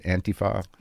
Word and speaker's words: Antifa: Was that I started to see this footage of these protests that would happen between, alt-right or white nationalists and Antifa. Is that how you say it? Antifa? – Yeah, that's how I say it.